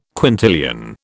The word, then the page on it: quintillion: British English